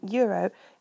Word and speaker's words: euro: Pronouncing the word euro in Italian is really very nice because you pronounce all the letters in the word. So rather than being euro, it's euro.